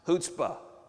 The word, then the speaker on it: chutzpah: It's pronounced chutzpah. Everybody say chutzpah.